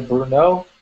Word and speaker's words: Brno: A pronúncia da cidade é Brunel.